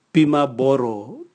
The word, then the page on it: bima boro: Raga